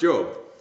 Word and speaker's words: Job: It's pronounced Job.